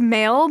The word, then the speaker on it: M(h)aol: The band is called Mail, but it is not spelled the way that you would think. It's spelled... – So it's spelled M-A-I-L, right? – Yeah, exactly, like like email. it's spelled M and then the letter H in parentheses and then A-O-L, Mail.